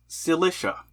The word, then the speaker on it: Cilicia: I wrote myself a pronunciation guide. It is pronounced Cilicia.